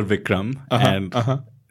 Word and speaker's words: Bikram: Bikram Yoga? – It's appropriate because... – Oh, my God. – A, because you're Vikram. That's actually the correct pronunciation of Bikram.